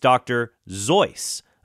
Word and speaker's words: Seuss: And he says his name rhymes with Dr. Seuss's name. It's Dr. Zeus.